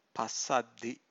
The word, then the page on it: passaddhi: Pali